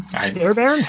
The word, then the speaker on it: Fairbairn: Because it's F-A-I-R-B-A-I-R-N. – So, yeah, Fairbairn? – Fairbairn? It sounds like it rhymes, but it shouldn't. Yeah, so it's Fairbairn,